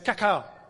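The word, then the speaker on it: Kaká: But because nobody can say that, nobody can remember that, people know him as Kaka. Accent on the second A.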